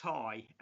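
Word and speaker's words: Tadhg: That'll be pronounced Ty, as our sorry. – Gaelic spelling works.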